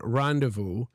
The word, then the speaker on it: rendezvous: A handful of French loan words have that special je ne sais quoi of a silent Z words. For example, including the word rendezvous or les affaires.